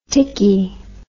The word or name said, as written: tickey